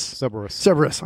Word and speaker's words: Cerberus: How do you say it? Cerberus? – Cerberus.